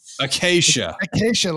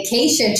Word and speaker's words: Acacia: Is with, make sure I'm saying this right, Acacia. – Like Acacia forgot. We're still here doing our 11,000 barrels a day. – That's right. Like the acacia tree. Yes, Acacia.